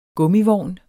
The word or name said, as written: GUMMIVOGN